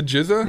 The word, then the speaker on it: GZA: Is it pronounced the GZA? – Yeah. – Yes.